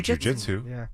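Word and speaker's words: jiu-jitsu: How do you say it? Jujitsu? – I say jujitsu. – Jujitsu? – Yeah. – Mm-hmm. Okay. Not jujitsu. Okay.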